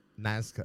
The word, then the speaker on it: Nazca: Nazca.